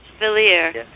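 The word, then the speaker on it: Valere: Valir is the way he pronounced it.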